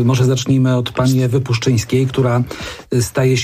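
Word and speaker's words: Puszczyńska: żeby słynny prezenter kaneńskiego Czerwonego Dywanu nauczył się wymawiać jej nazwisko, bo jak na razie wprowadzają ją jako Ewę Puzyńską.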